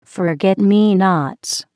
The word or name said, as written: forget me nots